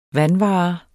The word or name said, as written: vanvare